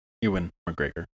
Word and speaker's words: Ewan McGregor: Ian McGregor. Ewan McGregor. I always say that wrong.